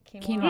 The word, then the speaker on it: quinoa: So if I had a quinoa dinner, like just, is that how you say it? – Quinoa.